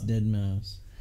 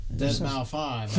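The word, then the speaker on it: deadmau5: It's Dead Mouse. – Dead Mouse 5. – That is not how it's pronounced.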